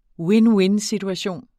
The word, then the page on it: win-win-situation: ˈwenˈwensidwaˌɕoˀn; wenˈwen-